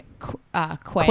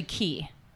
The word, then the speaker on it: Quay: It's like, you know those sunglasses like Quay? – Quay? – But it's actually Key. – What? – It's actually pronounced Key. – Wouldn't have guessed that. – I know. And I hate it. – Don't spell it A-Y if it's Key.